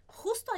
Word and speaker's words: justo: Justo, esse J, é aquele sonzinho aqui na garganta. Então, eu vou colocar ele com esse som do H.